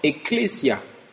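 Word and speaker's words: ecclesia (ἐκκλησία): Le mot église vient d'un mot grec que l'on prononce ecclesia.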